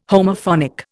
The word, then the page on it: Homophonic: Indian